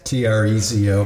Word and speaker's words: Trezo: So, talk to me a little bit, um, just first about like it's Trezo, right? How do, you, how do you pronounce it? Yeah, that's right. Trezo. T R E Z O, pronounced Trezo.